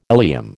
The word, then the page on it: ileum: American English